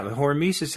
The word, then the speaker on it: hormesis: So the other thing is hormesis. Am I saying that right? – Yeah, hormesis, that's something we've talked about also.